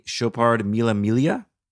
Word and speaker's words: Chopard Mille Miglia: So I've used a Chopard Mille Miglia to...